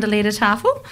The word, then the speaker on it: Liedertafel: Our school band performed a few songs, and if I'm correct, it's pronounced the Liedertafel, mm-hmm. – came to our school to do a couple of traditional German songs. – And that's some mature age. – The Tanunda Liedertafel? – Yes.